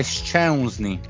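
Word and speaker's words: Szczęsny: Alla, Sce- no, è Scesni, non è Scesni perché la, la E di ha il simbolo sotto e si, si pronuncia Eun in polacco.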